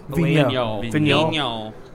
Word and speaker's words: Vigneault: Do you see any comparisons between Joe Girardi and Alain Vigneault? How do you pronounce his last name? – Vigneault.